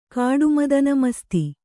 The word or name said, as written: ಕಾಡುಮದನಮಸ್ತಿ